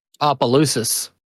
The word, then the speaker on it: Opelousas: Opelousas. – It is the A-S. You do say the A-S? – Yep. Opelousas.